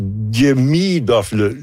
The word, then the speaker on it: Demidov: Il y a un petit Demidov. L'accent est sur la deuxième syllabe. Donc, Demidov.